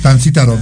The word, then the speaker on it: Tancítaro: Yo estamos viendo desde Tancitro, Michoacán. A ver si lo dije bien. Tancitro. Taro, Michoacán. No sé si dije bien. Tancítaro, Michoacán.